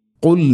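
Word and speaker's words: ഖുൽ (قُلْ): ഖുൽ മൻ കാന. ഖുൽ ഇവിടെ ശ്രദ്ധിക്കേണ്ടത് ഖാഫ് എന്ന അക്ഷരത്തിൻ്റെ ഉച്ചാരണം തൊണ്ടയുടെ മുഗൾ ഭാഗവും കഴിഞ്ഞ് ചെറുനാക്കിൻ്റെ ആ ഒരു ഭാഗം പ്രത്യേകം ശ്രദ്ധിക്കണം.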